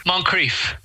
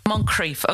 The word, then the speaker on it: Moncrieff: Um, your your how are you pronounced Moncrieff? Moncrieff? What is it? Moncrieff. Moncrieff. Okay.